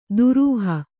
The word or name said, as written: ದುರೂಹ